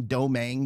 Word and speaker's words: Dominique: So like all good Cajuns from Cajun country, it's not pronounced like it looks. It's let's say doe like deer, mang like tang, domang. You put them together, that's what you get. So it's Nick Domang.